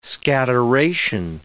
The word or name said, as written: scatteration